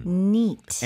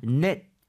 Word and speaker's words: neat: N-E-A-T. T가 네. – 되겠고요. 쌤, 이거 발음할 때 net 이렇게 해야 될까요? neat 이렇게 해야 될까요? – 이 발음을 강하게 해주셔서 neat. – 입을 좀 벌려주시면 도움이 될 것 같아요.